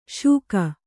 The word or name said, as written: ಶೂಕ